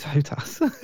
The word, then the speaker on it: HOTAS: Hot ass? Yeah, no, it's hot ass. That's how people pronounce it anyway.